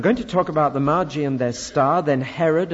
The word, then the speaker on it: Magi: First, we'll talk about the Magi. I still think that's the right pronunciation.